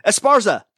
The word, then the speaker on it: Esparza: Esparza.